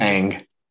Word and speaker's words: Ng: Is it just Kim Ng? – Ang. – Ang.